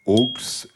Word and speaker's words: aulx: On dit que parfois, au pluriel, en effet, ce mot se dit aux et s'écrit A-U-L-X.